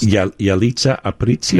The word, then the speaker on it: Yalitza Aparicio: Yalitza Aparicio. – There you go. – You even roll your R's.